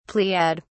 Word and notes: pleiad: British English

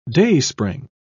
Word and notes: dayspring: British English

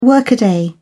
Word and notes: workaday: British English

